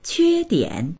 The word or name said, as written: que1dian3